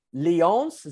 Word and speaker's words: Lyon: And it was in Lyons. Is that how you pronounce it? Lyons? L-Y-O-N-S? – Lyon. Lyon.